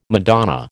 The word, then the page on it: Madonna: American English